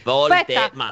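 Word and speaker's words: Woltemade: Allora, poi abbiamo già un nostro ascoltatore che deve fare l'esame in questi giorni su Volte Made, perché riuscire a sbagliare Volte Made è come si legge, Volte Made.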